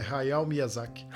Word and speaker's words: Hayao Miyazaki: Hayao Miyazaki, assim que pronuncia. Confirma isso é dele mesmo? – É, é dele. Ah, então. É Hayao Miyazaki.